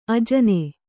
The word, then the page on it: ಅಜನಿ: kannada